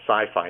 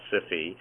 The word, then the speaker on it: SIFI: SIFI, which I think some people mispronounce. So if you have HIFI, which is Hi-Fi, WIFI, Wi-Fi, SIFI, to me is sci-fi, not SIFI.